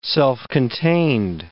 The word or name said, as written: self-contained